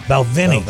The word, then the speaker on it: Balvenie: Balvenie.